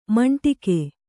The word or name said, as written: ಮಂಡಿಗೆ